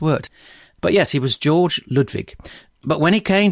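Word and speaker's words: Georg: His full German name was George without an E, which, as far as I can tell, is still pronounced George.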